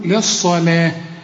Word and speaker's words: للصلاة: أولا الصلاة دي تكتب بالواو وتنطق بالألف زي ما انت شايفها كده، ورسم لك فوق الواو ألف عشان تحول الواو لإيه؟ إلى ألف، تقول للصلاة، لكن فخم لي الصاد ورقق لي اللام للصلاة من يوم الجمعة، من نون وبعدها ياء، والياء من حروف الإدغام هنا في غنة بقى، واخد بالك؟